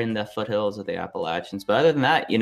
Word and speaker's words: Appalachians: I'm mostly excited that you correctly pronounced it Appalachians.